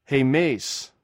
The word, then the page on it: hhmeis: greek